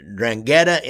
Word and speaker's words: 'Ndrangheta: This is going to be about the Drangheta in Australia, and I think I spelled I pronounced that right, didn't I, Cam?